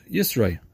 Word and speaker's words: Yisro: This week's parsha is called Yisro, or Yisro, or Yitro, depending on who taught you how to pronounce Hebrew. I'm going to say Yisro.